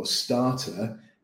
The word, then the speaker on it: starter: Okay, and the pronunciation tip for starter is the A is a long A, starter. That same sound that you have in the word car, Right, okay.